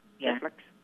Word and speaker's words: Keflex: E, rồi chữ F, L, E, X, Keflex. Đã đọc là Keflex, 500mg.